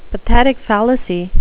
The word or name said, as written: pathetic fallacy